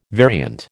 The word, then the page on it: variant: American English